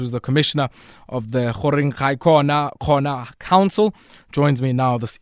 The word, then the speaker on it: Goringhaicona Khoena: So it's the Goringhaicona Khoena Traditional Council.